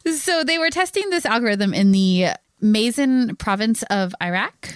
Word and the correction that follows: Maysan: Oh, Paul's listening to this going, that is not how you pronounce that. – No, it's not how you pronounce it. I'm so sorry, Paul. – Probably Maysan. – Maysan? Okay. Yeah. M-A-Y-S-A-N.